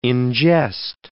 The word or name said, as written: ingest